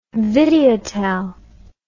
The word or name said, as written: videotel